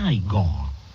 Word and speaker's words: Igor: Tu devi essere Igor. – No, si pronuncia Igor.